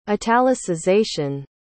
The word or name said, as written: italicization